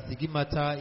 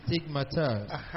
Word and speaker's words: stigmata: The man of God mistakenly pronounced as stigmata is supposed to be stigmata.